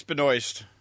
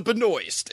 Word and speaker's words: Benoist: It's because, if you like this kind of show and you like Melissa Benoist, then go with Benoit. Is it Benoit or Benoist? – It's Benoist.